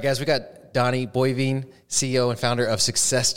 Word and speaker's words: Boivin: Is that the right way to pronounce it? Bovine. Bovine. Yeah, I saw it on, on Facebook and then I still said it wrong.